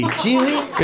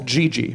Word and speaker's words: Gigli: How do you pronounce it? – It's fucking Gigli. – Gigli? – It's pronounced Gigli. – Yeah, Gigli.